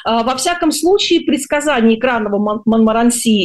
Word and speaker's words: Монморанси: Вот, и экранный Монморанси, да, ударение последний слог, предупреждает, что вообще вся страна может вспыхнуть.